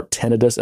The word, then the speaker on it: tinnitus: Or tinnitus as I've told it's pronounced.